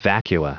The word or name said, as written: vacua